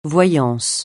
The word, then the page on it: voyance: francais